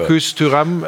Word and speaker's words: Marcus Thuram: Marcus Thuram, äh, ja, du, ich bin immer vorsichtig. Der Schmiso erzählt mir immer Sachen zur Aussprache. – Das war perfekt ausgesprochen.